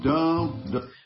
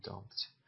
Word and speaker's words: dumped: We've been dumped. – We've been. اینجا باید گفت we have been. Have رو حذف کرده و نگفته. Have been dumped. این پی تلفظ نمیشه، نمیگم dumped. Dumped.